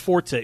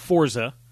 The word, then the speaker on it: Forza: It's kind of like Forza, which they announced is actually pronounced Forza. It's not Forza.